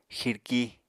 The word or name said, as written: xirki